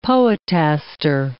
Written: poetaster

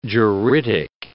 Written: juridic

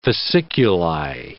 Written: fasciculi

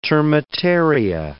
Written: termitaria

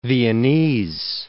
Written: Viennese

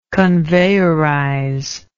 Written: conveyorize

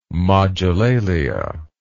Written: mogilalia

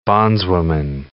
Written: bondswoman

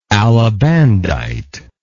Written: alabandite

